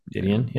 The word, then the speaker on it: Didion: We got a based on the book by Joan Didion. – Didion.